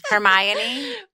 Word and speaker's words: Hermione: Hermione? How do you say her name? – Hermione? – Hermione.